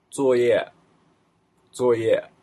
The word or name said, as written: zuoye